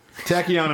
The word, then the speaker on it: tachyon: I don't know how to say this word. T-A-C-H-Y-O-N. – Tachyon? Said it so fast. – Tachyon? – T-H-C-H-Y-O-N. – It's Tachyon, Tachyon.